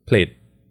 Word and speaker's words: Plaid: How, how do you pronounce that? Plaid. – Plaid, bro. – Plaid. – Plaid. – Plaid. – Plaid. Wow. P L A I D is plaid.